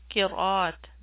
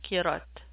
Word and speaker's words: qiraat: This is pronounced qiraat, not qirat.